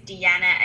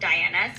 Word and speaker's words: Deanna: A lot of people still don't know that my name is Deanna and not Diana, so I appreciate.